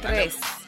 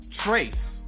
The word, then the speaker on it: Trace: Number three, Trey. Not good at pronouncing that one. – Trace. – Yeah, there's an S at the end. I'm so hesitant on the S. Trace.